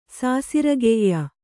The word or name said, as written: ಸಾಸಿರ್ಗೈಯ